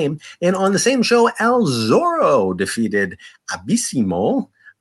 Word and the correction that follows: Abismo: Uh, sorry, Abismo, uh, Negro Jr., Mr. Nebula, and Alan Stone in an elimination match to win the 2008 Rey de Reyes tournament.